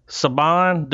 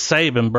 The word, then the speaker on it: Saban: Saban, dude, it's fucking Saban, dude. It's spelled just like Nick Saban. It's Saban, bro.